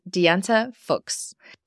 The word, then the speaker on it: Diante Fuchs: My name is Diante Fuchs.